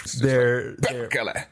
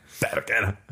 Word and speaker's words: perkele: Perkele. – Perkele. – You might have to roll your R's a little bit, but I won't.